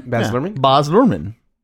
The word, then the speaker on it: Baz Luhrmann: Baz Luhrmann. But why are you saying it with an accent though? – Because he's kind of like he's got Baz Luhrmann.